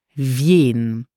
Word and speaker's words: wien: Whenever you want to say who, you would say in Luxembourgish wien. Listen carefully again for the pronunciation.